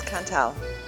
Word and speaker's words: Cantal: First of all, have I pronounced it correctly? – Yes, Cantal.